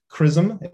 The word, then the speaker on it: XRISM: And that is what we are doing now with a mission called CRISM. If you take nothing else away from my talk, that is how we pronounce this word, X-R-I-S-M, CRISM.